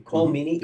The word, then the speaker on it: Colm Meaney: Colm Meaney.